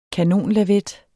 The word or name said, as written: kanonlavet